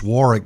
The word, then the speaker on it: Warwick: It's pronounced Warwick, but spelled W-A-R-W-I-C-K. The American pronunciation would be Warwick, I suppose, but the British call it Warwick.